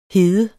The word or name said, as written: hede